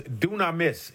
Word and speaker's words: dunamis: Now, the Greek word for power is dunamis. It's spelled D-U-N-A-M-I-S. Dunamis. D-U-N-A-M-I-S. Dunamis. And this is the term from which we get the English word dynamite.